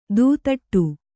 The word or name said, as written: ದೂದಟ್ಟು